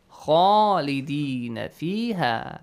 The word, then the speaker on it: خالدين فيها: So, the first important rule here, خالدين فيها, the pronunciation of the letter Kha is important since it's a heavy letter and it has an alif mad with it. So you gotta pronounce it with an A sound.